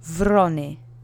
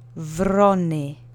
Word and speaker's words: wrony: Try to roll your R if you can. The accent is on the first syllable. Repeat after me three times. Wrony.